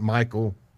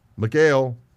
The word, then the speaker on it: Michal: I don't want money for my daughter, Michal." Some pronounce it Michal. Michal.